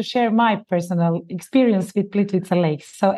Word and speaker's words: Plitvice: Nearby is Plitvice. Am I saying that correctly, or is it Plitvice? – Plitvice.